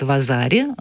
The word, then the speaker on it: Вазари: а, юка это э, самая минимальная оценка потом идет Вазари, но пишется как Вазаари, просто говорится Вазари.